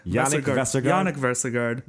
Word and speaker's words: Yannick Vestergaard: It is Yannick Vestergaard? – Yannick Vestergaard.